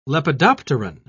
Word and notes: lepidopteron: British English

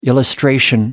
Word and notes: illustration: American English

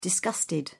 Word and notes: disgusted: British English

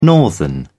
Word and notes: northern: British English

